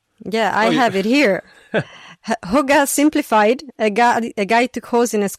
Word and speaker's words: hygge: It's, what's that Danish term? I think it's hygge. – Hygge is how you pronounce it.